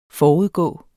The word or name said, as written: forudgående